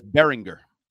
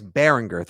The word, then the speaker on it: Behringer: By the way, it's Behringer. – It's a hard G. Okay. Hard G. So it is booty, not bootay, and it's a hard G. It's Bryce Behringer.